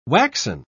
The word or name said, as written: waxen